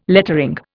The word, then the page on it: lettering: Indian